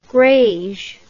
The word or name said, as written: grege